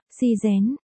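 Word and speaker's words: si dén (死人): Tử nhân, thì đọc là, si dén,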